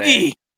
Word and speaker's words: Hoge: Uh, Tom Hoge. What a scumbag. – Hoagie.